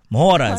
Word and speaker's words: Mhoro: I had to Google how to say hello in Shona. So, Mhora. Mhora is how you say.